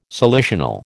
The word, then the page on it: salicional: American English